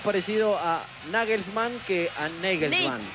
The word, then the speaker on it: Nagelsmann: ¿Cómo es el técnico de Alemania? – Yo no lo pienso pronunciar. – Es Nagelsmann. – Claro, es Nagelsmann. – ¿Cómo suena? – Es Nagelsmann, pero yo consulté algunas páginas que...